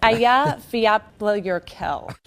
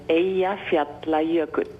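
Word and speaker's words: Eyjafjallajökull: Ayaflaya Yerkaval. It's Aya Fiapla Yerkel.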